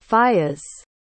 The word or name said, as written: fiyas